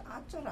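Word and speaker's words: ācāra: It's not Mitcha Chara, Mitcha plus Chara, because Achara, I cannot find it. – It's a long A, Achara.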